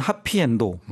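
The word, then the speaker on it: 하피엔도 (はっぴいえんど): 이분은 이제 하피엔드, 그러니까 영어로 하면 해피엔드인데 일본어로는 하피엔도라고 맞아요. – 이렇게 발음을 하죠.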